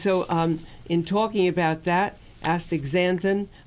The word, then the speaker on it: astaxanthin: Astaxanthin. There's no G in there. It's astaxanthin. A-S-T-A-X-A-N. The X is pronounced Z. Astaxanthin.